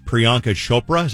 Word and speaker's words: Priyanka Chopra: Babies for Kendall Jenner, Priyanka Chopra, is that how you say her name? – Yep.